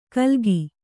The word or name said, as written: ಕಲ್ಗಿ